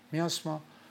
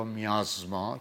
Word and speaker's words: میاسما: میاسما الان یا میازما که خب تلفظ فارسی میاسما آسان تره،